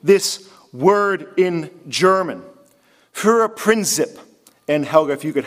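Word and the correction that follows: Führerprinzip: help me, I know I butchered that, I'm sorry. Or Gerda, please, forgive me. Führer Prinzip, it means the Führer's word is above law.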